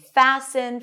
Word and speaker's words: fasten: This is why the spelling is not consistent with the pronunciation, but the T-E-N pattern changed and the T was no longer pronounced. And we see it in words like soften and fasten, fasten your seatbelts. We don't pronounce the T here.